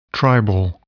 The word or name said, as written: tribal